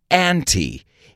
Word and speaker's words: ante: Ante se pronuncia ante e indica apostar dinero antes de desvelar las cartas.